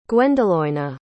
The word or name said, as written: gwendoloena